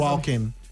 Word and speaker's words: Joaquin: Can you stop saying Walking Phoenix? – Why? You don't like the guy. – What? Pronounce it as what? You want to say? – Joaquin. – Yes, you Anakin. See, it's Joaquin Phoenix.